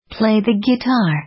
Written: play the guitar

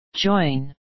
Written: join

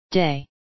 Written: day

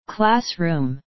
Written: classroom

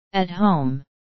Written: at home

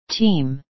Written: team

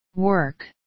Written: work

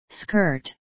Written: skirt

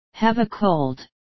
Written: have a cold

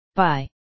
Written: by

